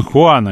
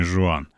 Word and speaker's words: Хуан: И Дон Хуан, а не Жуан.